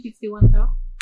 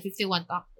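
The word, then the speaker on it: 51Talk: And is it in 51 talk? – Yes, correct. Okay. In 51 talk. It's 51, actually it's 5-1 talks, right? – 5-1 talk. – 5-1 talk. That's how they say it.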